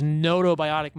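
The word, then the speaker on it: gnotobiotic: They introduced only 12 species into these notobiotic mice. – Right. – That's spelled G-N-O-T-O.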